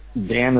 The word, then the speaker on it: Dan: Dan is spelled D-A-N.